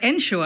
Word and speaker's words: ensure: But, you know, in something like ensure and insure, we have a clear distinction, don't we, between the meanings, even though it's, again, pronounced ensure, because we swallow any vowel that's not stressed.